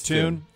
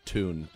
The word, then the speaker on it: Thune: I think it's Tune. – All right. I got him confused. – How's it spelled? – T-H-U-N-E. – Then it's Tune, because he puts pronunciations on here as opposed to their actual names. – Okay. – And there if there's one that needs to be done. So he's got it as Tune.